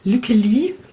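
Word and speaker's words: Lykke Li: Dann gibt es ein All-Female-Festival in L.A., habe ich gesehen, von Lücke Lee, wird sie so ausgesprochen.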